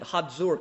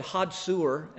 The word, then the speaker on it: Hazor: That's how they pronounce it. Hazor, kind of got a D in the middle of it. When we were over in Israel, they said Hazor, and I thought, that sounds like hot sewer.